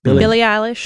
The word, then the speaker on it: Billie Eilish: Billie Eilish.